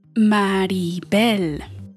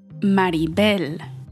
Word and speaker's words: Maribel: Maribel, Maribel. That's how you say my name. Not Maribel, not Mary Bell, not Marvel.